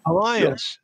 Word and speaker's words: Alliance: Am I saying that correct? – Alliance. – Alliance. – Yeah. Yeah, I was using the French pronunciation.